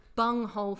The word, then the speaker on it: bunghole: It really sounds the same when I pronounce it, so I feel like I should spell it out for any international listeners. – B-U-N-G-H-O-L-E. – B-U-N-G-H-O-L-E. – Yeah. – I'm not saying B-U-M-H-O-L-E. Very different things.